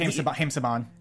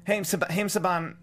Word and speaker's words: Haim Saban: And Haim Saban. – Haim Saban, I pronounced his name wrong.